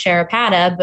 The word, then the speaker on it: Sharapata: So my last name is pronounced Sharapata,